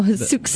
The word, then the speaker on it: Sioux: The city was able to halt a movement to start pronouncing the X in Sioux.